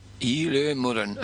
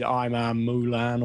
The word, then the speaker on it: Emer Mullen: Okay, we said it wrong, did we? – Yeah. I think I said Imar Mulan or something like that. She's an Irish girl, but it looks like it's Emer Mullen.